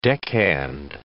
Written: deckhand

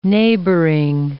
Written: neighboring

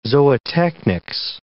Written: zootechnics

